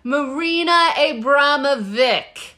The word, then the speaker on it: Marina Abramović: I had to listen to it like 30 times to get it right. But it is Marina Abramović.